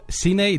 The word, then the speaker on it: cine: Y bienvenido a Obsesivo Cine Discurso. I did cine, which is the South American pronunciation.